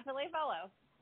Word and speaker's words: Ville Valo: And she's like, well, um, I can never remember how to pronounce this guy's name. The, the guy from HIM, Ville Valo, or you. Oh yeah, yeah, um, that's exactly right. Yeah, Ville Valo.